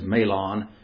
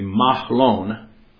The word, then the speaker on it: Mahlon: And the name of the one, and you can pronounce it different ways. Probably the American way is Mahlon. In the Hebrew, the way to pronounce it is actually Mahlon.